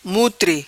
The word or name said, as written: Mutri